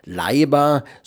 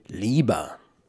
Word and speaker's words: Leiber: Der Mann heißt natürlich nicht Jerry Leiber, so sprechen das Deutsche aus, und ich bin ja Deutscher, sondern die englische Aussprache müsste natürlich heißen und heißt Jerry Leiber.